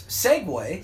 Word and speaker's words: segue: Segway. You've heard me talk about that. Segway is spelled S-E-G-U-E, and it's pronounced segue.